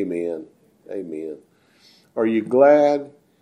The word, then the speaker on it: amen: I told you one time it's actually pronounced in the Greek, amen. But that sounds funny because we've said it for so long, you know, amen, or a lot of people say amen. Amen is closer to the way it's pronounced, actually.